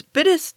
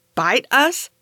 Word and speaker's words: Bitis: At first, I thought it was pronounced like bite us, which I thought was hilarious, and I was disappointed to find that it's pronounced bit us, although that's actually funny, too. Also, it's not pronounced bit us, actually, it's bit is, bittus. It's spelled B-I-T-I-S.